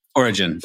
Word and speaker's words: Origen: I think it's Origen. Is that right? – Origen. I say Origen. Otherwise, Origen. It just sounds like the state. – But they put up a quote by Origen because it fits their sermon.